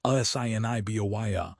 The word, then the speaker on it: Assiniboia: Finally, for the bettors looking at the late pick four at Assiniboia Downs, pronounced Assiniboia, on July 21st, the math is straightforward.